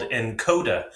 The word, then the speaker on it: nkoda: Just wanted to let you know that today we're being brought to you by an app called Encoda. That's spelled N-K-O-D-A.